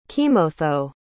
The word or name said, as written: kimotho